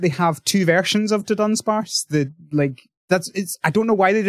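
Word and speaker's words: Dudunsparce: Everybody's been saying Dundunsparce. There's no first N.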